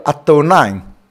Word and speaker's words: Adonai: 因为希伯来人的圣经在 YHWH 的地方他都会写一个 Adonai, A-D-O-N-A-I, Adonai.